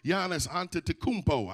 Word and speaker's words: Giannis Antetokounmpo: Across the field, he has a brother by the name of Giannis Antetokounmpo.